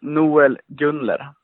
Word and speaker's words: Noel Gunler: Hey, you can say it like that, but in Sweden we say Noel Gunler.